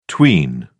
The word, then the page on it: tween: British English